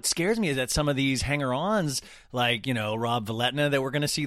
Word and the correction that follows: Valletta: How do you say? – Valletta. Hello. Valletta, sorry.